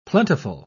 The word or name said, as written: plentiful